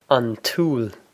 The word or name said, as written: an t-úll